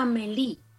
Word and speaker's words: Amelie: Se escribe Amelie, pero se pronuncia Amelie.